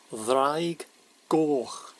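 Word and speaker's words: Ddraig Goch: It's pronounced thraig. Gorch. Thraig. Gorch. Thraig is dragon. Gorch is red.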